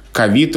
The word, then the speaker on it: ковид: Еще были вопросы, когда только-только появилось это слово, как правильно говорить, ковид или ковид. Но кажется, что сейчас даже этот вопрос уже можно и снимать, потому что ковид употребляется в подавляющем большинстве случаев именно с таким ударением.